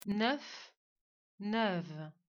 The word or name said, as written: la Navarre